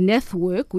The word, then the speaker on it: Net#work: So our name technically is Network, which is N-E-T-H-W-O-R-K. – Okay. – And try and say Network on reception all the time. So we actually made it a silent H back 20 years ago before the hashtag was famous.